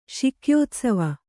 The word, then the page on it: ಶಿಕ್ಯೋತ್ಸವ: śikyōtsava